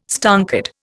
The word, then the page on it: stonkered: Indian